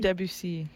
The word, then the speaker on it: Debussy: How do you say it? Debussy? – Debussy. – Oh, yes.